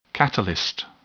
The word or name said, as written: catalyst